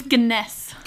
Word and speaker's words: Gunness: That's all I can think of when you say Belle Ganesh. – It's Ganesh. Like, almost like Guinness, but with no I.